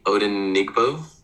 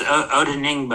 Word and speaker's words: Odenigbo: John Johnson, Troy Hill, Tack McKinley, Malik McDowell, Anthony Walker, and I might butcher this name, Efedi Odenigbo? – Yeah, it's pretty close. Odenigbo, I think, but yeah, pretty close.